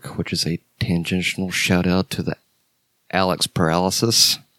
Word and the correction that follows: Perialis: That's not how he pronounces his name. It's Alex Perialis, but... I think on a prior episode, we called him Alex Paralysis.